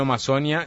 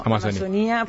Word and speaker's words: Amazonía: La gente me está diciendo, ¿por qué es Amazonía y no Amazonia? Si es en portugués. – No, Amazonía es Amazonía. En español lleva tilde la I.